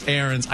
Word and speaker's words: Ariens: Ariens, pronounced Ariens.